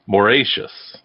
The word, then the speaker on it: Mauritius: I even phonetically wrote it and I still said it wrong. Mauritius.